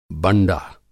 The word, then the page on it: बंडा: Hindi